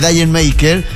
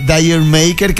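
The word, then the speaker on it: D'yer Mak'er: Y el amigo le pregunta: Jamaica, que en los ingleses suena muy parecido a decir la obligaste. Y él dice: no, quería ir. Y por eso, en vez de Jamaica o la obligaste, apareció este Dayer Maker, que se escribe con una D, una rayita arriba, Dayer Maker.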